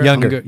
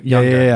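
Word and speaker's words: Junger: Also, good book, Tribe, Sebastian Unger. – Younger. – Younger. Never say it right.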